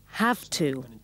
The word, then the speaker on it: have to: First, with have to. Notice the pronunciation. It's more like an F. Have to.